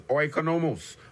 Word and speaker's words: oikonomos: The Greek word is oikonomos. Oikonomos. Spelled O-I-K-O-N-O-M-O-S. Oikonomos. I spell it again. O-I-K-O-N-O-M-O-S.